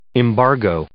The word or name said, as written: embargo